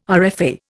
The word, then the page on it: RFA: Indian